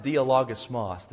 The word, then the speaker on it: dialogismos: The Greek word that's translated doubting is pronounced dialogismos.